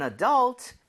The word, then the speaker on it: adult: All right, so one for an adult and one for a 12-year-old. – old. – Okay, 정답 맞습니다. 성인이라는 단어, adult, right? – 네. – 자, 강세를요. U에다가 찍고 아. – 발음을 하면 좋습니다.